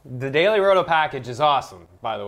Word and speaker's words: Daily Roto: We're brought to you today by Daily Roto, which I always find a way to say it wrong. It's Daily Roto.